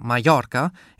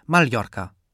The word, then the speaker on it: Mallorca: Offiziell wird das spanische Doppel-L, genannt Elje, mit einem leicht anklingenden L gesprochen, sowie im deutschen Wort Familie. Aber für Spanien gilt dasselbe wie für Deutschland. Überall spricht man anders. In der Umgangssprache hat sich das Elje zu einem J verschliffen, sodass die Form Mallorca inzwischen häufiger zu hören ist als Mallorca.